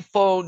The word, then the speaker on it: pho: Here in Anthem, don't forget Pho Legend, Pho. We say pho noodles when we think about pho Vietnamese noodles, but you really pronounce it pho.